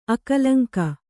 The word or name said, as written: ಅಕಳಂಕ